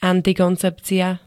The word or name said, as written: antikoncepcia